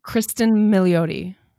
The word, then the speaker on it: Cristin Milioti: Please say her name again for me cuz I can't Cristin Milioti.